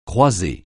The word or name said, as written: croiser